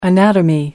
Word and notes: anatomy: American English

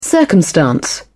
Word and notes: circumstance: British English

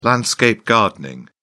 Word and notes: landscape-gardening: British English

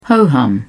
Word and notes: ho-hum: British English

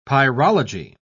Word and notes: pyrology: British English

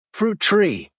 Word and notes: fruit tree: American English

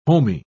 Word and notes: homy: British English

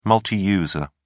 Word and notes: multiuser: British English

